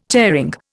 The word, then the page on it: tearing: Indian